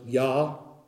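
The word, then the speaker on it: Jah: It's J-A-H here in my King James, and I believe it's pronounced Yah.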